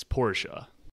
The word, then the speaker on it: Porsche: people say Porsche. – Yeah, but for sure. – the actual pronunciation is Porsche.